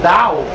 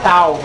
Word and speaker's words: Tao: Vocês vão ver que existe uma grande ligação entre os conceitos do Tao, né? O pessoal aqui fala Tao, mas a pronúncia correta é Tao.